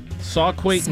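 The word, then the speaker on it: Sauquoit: Sauquait, New York.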